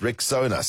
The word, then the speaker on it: Rexona: It's pronounced Rexona, simple, strong, and easy to remember.